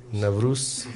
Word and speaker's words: Navruz: Nariz. – Скажите, что Кришна является цветами Нариз. – How do you say? Navruz. Navruz.